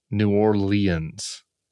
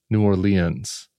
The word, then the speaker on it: New Orleans: Or I was on MS Now yesterday with former Mayor Mitch Landrieu, who, and I love that he calls our city New Orleans. New Orleans. New Orleans. Four syllables.